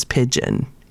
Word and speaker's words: pidgin: I don't know if I'm saying that right, but it's spelled P I D G I N. – I always heard the Hawaiian kids say "pidgin." Okay, good. I'm, I'm at least saying it correctly.